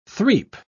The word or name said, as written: threap